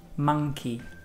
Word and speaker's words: monkey: La pronunciación correcta sería monkey. Monkey. – Es como una A más que una O. Monkey.